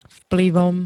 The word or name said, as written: vplyvom